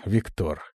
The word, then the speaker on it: Виктор: «Привет, дух!» «Зови меня Виктор», — поправил я с ударением на «о».